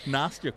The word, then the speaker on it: Nastia: Just like Nastia, correct? – Yes. – Did I say that correctly? – You did.